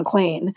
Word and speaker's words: Klain: And there's no one in Washington who has any confusion about how you say Ron Klain. It looks like rain and Spain and plane and Maine and vein, and it rhymes with all of those things.